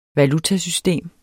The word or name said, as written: valutasystem